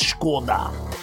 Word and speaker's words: Skoda: Skoda. That is how it's pronounced. Skoda, not Skoda.